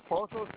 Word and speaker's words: Pozos: Okay, Pozos, it is P-O-Z-O-S Road.